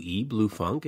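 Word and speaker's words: Blue Phonque: He's got a new band called Blue Funk, and it's spelled P-H-O-N-Q-U-E, Blue Funk.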